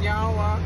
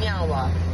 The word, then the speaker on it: nyawa: So in our language, we say nyawa, which means thank you. – Nyawa. – Nyawa. – I said it right? – Yeah, you did.